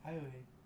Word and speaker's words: Ayoade: Ayoade. – It's A-Y-O-A-D-E. – Ayoade. – That's not even how it's... It's like Ayoade.